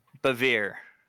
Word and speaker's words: Bevere: Bevere. Yeah. – Okay, cool. I've been fucking it up. Anyway, can I say that on this? – Rhymes with, okay? Absolutely. Rhymes with severe.